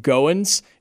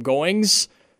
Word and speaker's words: Goins: Jacob Goins. It's G-O-I-N-S. But the amount of times that I was called Jacob Goings in my lifetime is pretty astronomical.